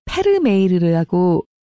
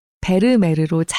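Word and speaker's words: Vermeer: 좀 전에 낭독 중에서 제가 베르메르라고 읽었는데요. 사실 이 책에서는 우리말 발음으로 페르메이르라고 표기하고 있습니다. 아마 그게 실제 발음에 더 가까울 겁니다. 하지만 우리나라에서는 워낙 베르메르로 잘 알려진 화가라 저는 일부러 베르메르로 읽었습니다.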